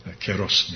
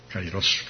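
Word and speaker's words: kairos (καιρός): Bezmjerna mjera i dakle ogleda se ovaj svijet, njegovo vrijeme, njegov keros, njegov kairos, kako izgovaraju Erazmijanci,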